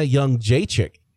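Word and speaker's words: Jędrzejczyk: Someone who should have zagged and has a lot of zags in her name is Joanna Young Jacek. You don't pronounce any of the Z's, but they're there.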